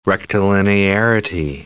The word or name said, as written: rectilinearity